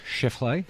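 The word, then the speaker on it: Scheffler: But Shefali, is that how you say his name? He's 18 under. – Shefley. – Shefali. – Shefali. – Shefley. The wrong emphasis on the wrong syllable.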